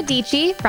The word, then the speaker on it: Dietschy: Sarah Dietschy, rhymes with peachy,